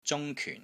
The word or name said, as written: ZUNG1 KYUN4